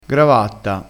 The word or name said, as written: gravatta